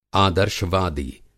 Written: आदर्शवादी

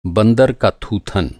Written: थूथन

